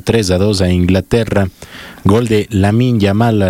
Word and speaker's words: Lamine: Con gol de Lamine Yamal, a los dos minutos puso Lamián a ganar. – Lamine. Se escribe Lamine Yamal.